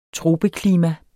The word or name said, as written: tropeklima